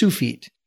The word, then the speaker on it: Tsufit: It's spelled T-S-U-F-I-T, but it's pronounced Sufit.